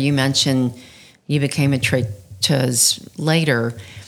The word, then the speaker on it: traiteuse: And the female form of the word is traiteuse. – S-E. – It sounds like a Z sort of at the end, if I'm even pronouncing it right, but I think I am. It ends in S-E, but it's traiteuse.